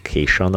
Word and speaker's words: Kacian: It's spelled K-A-C-I-A-N, and she put it is spelled as in syndication, so it's like Cynthia Cation or something like that.